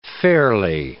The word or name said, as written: ferlie